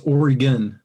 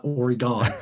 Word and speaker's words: Oregon: Yeah, it's Oregon, not Oregon.